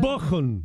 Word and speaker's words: Bochum: Bochum.